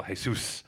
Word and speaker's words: Jesus: Jesus, actually. He's Brazilian. You pronounce the J. – Mr. Brazilian. – We're doing a lot of pronunciation things. – Yeah, right. So let's get into this linguistics, okay? – It's Portuguese. – Welcome to the Linguistics Yahoo podcast. – I've been to Brazil twice and Portugal. And, well, first of all, just to clarify, it is Jesus because you pronounce the J's like Jose Mourinho. It's not Jose, even though people get it wrong in England still.